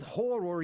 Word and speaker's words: horror: It seems that our entire world wants us to live with horror year round, so why not? – And two syllables, friends, don't forget that.